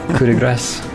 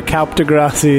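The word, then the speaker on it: coup de grâce: Can we just Kaup de Grassi the rest? – Coup de Grasse. – I'm pretty sure Kaup de Grassi is the correct pronunciation. – Yeah, I've heard it both ways.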